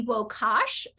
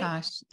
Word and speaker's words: Wokasch: I have Missy Wokasch. I got to learn how to say that name because it has more letters than I would have pronounced correctly myself, but I got it right.